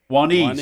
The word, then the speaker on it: Juanice: Juanice. – And it's spelled J-U-A-N-I-C-E. If you leave the U off, it spells Janice.